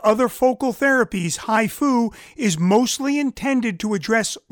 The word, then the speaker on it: HIFU: HIFU, and that is spelled H-I-F-U, which stands for High Intensity Focused Ultrasound, is a procedure that is targeted or a focal tumor treatment.